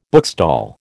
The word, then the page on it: bookstall: American English